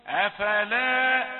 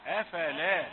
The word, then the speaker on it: أفلا: أفلا يعني الحرفين الأولين يجوا متتابعين ولا يبان صوت كأنه صوت منفصل لكنه متصل. ما أقولش أفلا، أفلا هتبقى زي فلما أفلا، أفلا وهكذا. ولا تتأنى في النطق بالكلمة لأنها مكونة من يعني ثلاثة مقاطع.